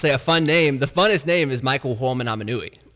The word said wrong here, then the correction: Manawanui: Oh, you almost got it right. – I could never pronounce it. – Did I say it wrong? – It's Michael Manawanui. – Oh, okay. Aminui. – He's so funny. – Aminui. – I'm dead serious. Michael Manawanui. – I knew his nickname was Uh-oh. – Yeah. That's how you pronounce it. Uh-oh, Manawanui. – Uh-oh, Manawanui. Okay.